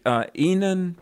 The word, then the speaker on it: Enon: And brother, is it Enon Presbyterian? Am I pronouncing that correctly? – You are. E-N-O-N.